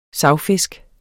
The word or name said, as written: savfisk